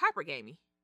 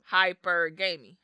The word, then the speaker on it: hypergamy: I believe it's pronounced hypergamy. – Okay. – That's just using my kindergarten or, you know, elementary school way of putting words together. – Yeah, I don't. – Hypergamy. – Hypergamy. Is it gamey or gammy? – Hypergamy. Oh, you might be right. Hypergamy. – Exactly. So we don't know how to pronounce this word, but H-Y-P-E-R-G-A-M-Y.